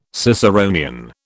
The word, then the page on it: Ciceronian: British English